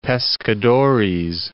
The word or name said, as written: Pescadores